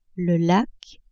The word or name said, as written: lac